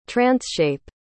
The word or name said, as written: transshape